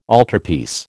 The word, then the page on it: altarpiece: American English